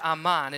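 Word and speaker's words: aman (אמן): But those three Hebrew consonants there is the Hebrew word, root word, that leads to amen, and that is the word aman. It's aleph, mem, and nun.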